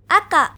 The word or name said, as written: aka